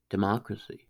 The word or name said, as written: democracy